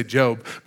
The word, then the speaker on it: Job: Job, that's not really how you say it. You say Job.